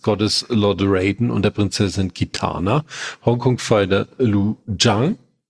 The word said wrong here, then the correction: Liu Kang: Habe ich das jetzt, oder wie wird er richtig ausgesprochen? – Liu Kang, glaube ich, wird er ausgesprochen. Kang.